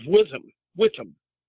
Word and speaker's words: Witham: So, you know, this caller, my DNA is traceable to the Abbeys of Witham. I have to learn how to pronounce my H silent. – The H is silent, yeah.